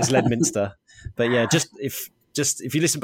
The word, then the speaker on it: Leominster: It's a town of about 20,000 people, and it's called Leominster, and it's spelled Leominster. So my GPS always calls it Leominster.